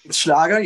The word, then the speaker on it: Schlager: is Schlager. Is that the right way to say it? – Schlager, yes.